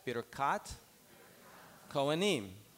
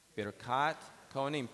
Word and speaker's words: Birkat Kohanim: Birkat Kohanim. One more time. Birkat Kohanim.